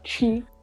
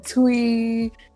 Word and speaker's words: Twi: Oh, I don't think you'll be able to pronounce it, but it's like chi. It's like chi. That's how you pronounce it. Most people say chi, twi. It's chi. Like, it's spelled T-W-I.